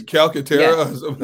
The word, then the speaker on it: Calcaterra: Well, I, I would just say that the tight end that that uh, uh that they drafted, uh, uh, okay, uh, Grant Calcutta. Uh, um, I'm mispronouncing the last Calc- name. Calcaterra. Yeah, yeah, yeah Calcaterra. Sorry yeah.